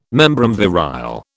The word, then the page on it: membrum virile: British English